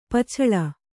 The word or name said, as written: ಪೊಚ್ಚಳ